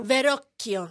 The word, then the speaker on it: Verrocchio: Am I pronouncing that right? Verrocchio? – Verrocchio. – Verrocchio. – Are there two C's followed by an H? – There are two C's followed by an H.